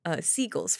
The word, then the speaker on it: Siegel: Sorry, I keep saying this guy's name wrong, like every time I say it in a different way. Siegel's version of Invasion of the Body Snatchers.